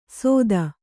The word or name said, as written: ಸೊತ್ತ